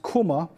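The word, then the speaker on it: kuma (κῦμα): It comes from number 2249 in the Greek, pronounced kuma, which is means to be pregnant or to swell.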